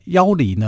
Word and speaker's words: 要离: 那要离呢？我们现在今天要讲的是另外一个人，叫要离。要就是要不要的要啊，这个字要读作要。离就是离开的离。